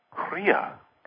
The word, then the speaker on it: Kriya: And, you know, have you heard of Akriya? – Akriya, no. – Yeah, it's spelled K-R-I-Y-A. – Kriya.